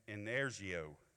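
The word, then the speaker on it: energeo: But Strong's Concordant lists a word that is pronounced in energio. Everybody say energio.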